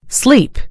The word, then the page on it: sleep: American English